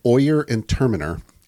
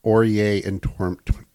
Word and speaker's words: Oyer and Terminer: But literally, each of these end in an R. Oyer and Torminer.